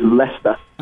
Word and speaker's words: Leicester: And Leicester is Leicester. – Oh, that's true. There's a Leicester, Massachusetts spelled exactly the same way.